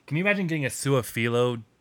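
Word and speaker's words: Su'a-Filo: As much as I hate to say it, I think the guard too that everyone's really in love with, Sua Fulu. How do you pronounce his name? – Sua Filo. I would say Sua Filo, but I'm not sure. – Sua Filo. Xavier Sua Filo.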